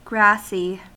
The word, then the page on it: grassy: American English